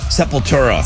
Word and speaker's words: Sepultura: Sepultura.